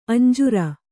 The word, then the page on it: ಅಂಜೂರ: kannada; añjūra